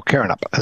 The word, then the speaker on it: Karrinyup: Well, there's a shopping centre in Perth here called Karrinyup, or Karrinyup is actually the way you sell it. K-A-R-R-I-N-Y-U-P. The Y is silent.